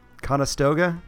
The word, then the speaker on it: Conestoga: So, August 1st, in 1955, the Conestoga, is that how you pronounce that? – Yeah, Conestoga Wagons.